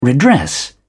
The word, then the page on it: redress: British English